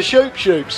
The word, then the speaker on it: Shoop Shoop: What, the Shoop Shoop song, which is spelled S-H-O-O-P, S-H-O-O-P song? Well, it's not spelled Chup song, is it?